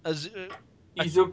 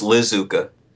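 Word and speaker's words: Iizuka: Izuka. – Izuka. I never pronounce it. – Yeah. I like when people think it's Lizuka.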